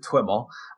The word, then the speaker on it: TwiML: Yeah, we pronounce it TwiML. So it's Twilio XML.